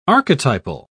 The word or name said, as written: archetypal